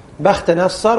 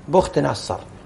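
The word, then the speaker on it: بختنصر: لكن على كل حال هي بخت نصر، وبعضهم ضبطها بالضم بخت نصر، والصواب بخت بفتح الباء.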